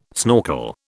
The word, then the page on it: schnorkel: British English